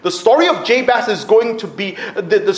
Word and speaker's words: Jabez: His mother had named him Jabez, which is how it's pronounced in Hebrew. We'll go for the Hebrew pronunciation. Jabez, saying, "I gave birth to him in pain."